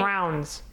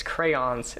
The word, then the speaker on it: crayons: Because if you listen to episode 9, yay, I pronounced it crowns. – You have that old, you have that old southern accent. – Accent. – Is it southern? All I know is crayons. That there's a whole shitload of people from where I'm from that used to say it as crowns, and it drove me nuts. It's like, it's crayons. It's two syllables.